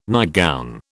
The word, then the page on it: nightgown: British English